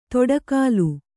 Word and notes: ತೊಡಕಾಲು: toḍa kālu

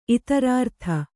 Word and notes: ಇತರಾರ್ಥ: itharārtha

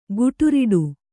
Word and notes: ಖೊಟ್ಟಿರೊಟ್ಟಿ: khoṭṭi roṭṭi

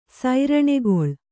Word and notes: ಸೈರಣೆಗೊಳ್: kannada

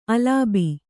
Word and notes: ಅಲಬು: Kannada

